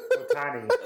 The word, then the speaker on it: Otani: All right, I'll sign a couple Otuna. Of White Sox stuff. – Yeah, was it Otuna? – No. How do you pronounce it? – Otani.